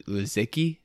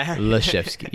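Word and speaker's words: Laszewski: He also was guarding that guy from Notre Dame, Leszewski. That's his name. That's how you say it. It's because I remember it was kind of like Krzyzewski, but it's Leszewski.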